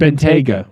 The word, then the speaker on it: Bentayga: Bentayga.